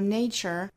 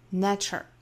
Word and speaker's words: nature: Of our nature，那 nature 这个词不要读成 Nature，应该是 nature，注意这个元音啊。我们知道由它而来的一个形容词的发音呢是 natural，那里面的元音是 a，但是这个词名词形式第一个元音是 a，双元音 nature，所以不要混淆这两个词的发音了。